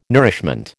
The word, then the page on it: nourishment: American English